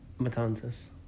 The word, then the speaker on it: Matanzas: In 1880, Dr. Westcott built a luxurious 16-room house overlooking the scenic Matanzas Bay. Is that how do you say that? – Matanzas. – Matanzas. – Yeah, right.